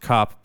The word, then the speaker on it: Kap: All right, this stars Byung-hun Lee, Min-seok Choi, Joon-hyuk Lee, Gook-hwan Jeon, Kap-soo Kim, and I know that's pronounced cop because I work with a lot of Burmese people.